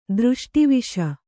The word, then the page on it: ದೃಷ್ಟಿವಿಷ: dřṣṭi viṣa